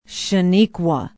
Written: chenequa

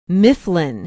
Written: Mifflin

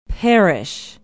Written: parrish

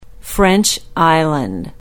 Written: French Island

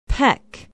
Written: peck

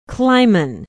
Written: Clyman

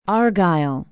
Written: argyle

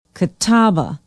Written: catawba

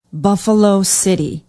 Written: buffalo city

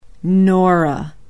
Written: nora

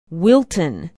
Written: wilton

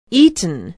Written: eaton

